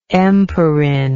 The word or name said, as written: empirin